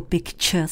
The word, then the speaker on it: picture: A to slovíčko picture si rozdělte na 2 části. Picture. A už je vám jasné, že tu část, kterou bychom česky četli jako ture, anglicky vyslovujeme jako č.